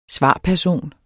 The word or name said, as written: svarperson